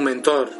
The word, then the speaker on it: Mentor: Pero algunos os preguntaréis lo que no estuvisteis el otro día, ¿qué es un mentor? Mentor es una palabra de origen griego que aparece en la Odisea y que es el nombre de una persona. Realmente se pronuncia mentor.